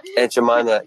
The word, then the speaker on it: Aunt Jemima: Jemima, I don't know how to say it. – Aunt Jemima, yes.